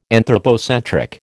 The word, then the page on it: anthropocentric: American English